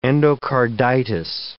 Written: endocarditis

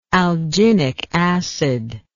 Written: alginic acid

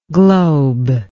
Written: globelike

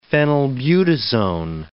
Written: phenylbutazone

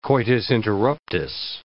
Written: coitus interruptus

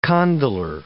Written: condylar

